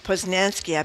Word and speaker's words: Posnanski: Michael Schur and Joe Posnanski, I believe is how you pronounce his name. – Yep.